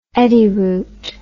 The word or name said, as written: eddyroot